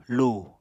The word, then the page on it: lu: Apatani